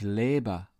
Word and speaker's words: lebe: Make sure you make the difference between ich liebe, I love, and ich lebe, I live or reside.